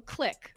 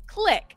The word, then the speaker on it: Qlik: So this was a software firm called Kilk. Hope I'm pronouncing that correctly. Click. So sorry. I pronounced that wrong. Click. Q-L-I-K.